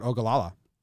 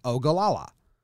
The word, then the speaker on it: Ogallala: Ogallala. Ogallala. But I was listening today and they put the emphasis on the O, because the way I have it here is Ogallala.